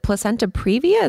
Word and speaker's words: placenta previa: But you talked about, I was reading about, what is it, placenta previa? Is that how you pronounce it? – Yes, yes.